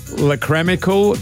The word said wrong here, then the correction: lacrimal: carungles. – That is not how you say it, I'm sure. – Caruncle. – What? – A lacrimal caruncle.